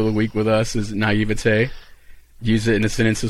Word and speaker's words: naivete: We had tons and tons and tons of people message us with the correct pronunciation of naivete.